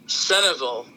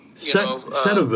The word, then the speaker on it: Centralville: they pronounce it Centerville. – Centerville. – But it's spelled Centralville, but it's said like it's Centerville.